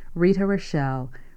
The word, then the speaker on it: Rochelle: First, my name, and I'll be happy to spell that for you, Rita Rochelle.